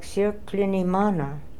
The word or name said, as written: sirklinimana